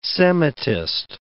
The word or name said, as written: Semitist